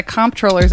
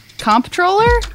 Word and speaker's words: comptroller: Comptroller? – Because it's supposed to be pronounced controller.